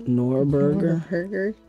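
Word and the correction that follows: Norgorber: Norgerber. Sorry, I said it wrong. – Norgerber. – Like a Nordic Gerber baby.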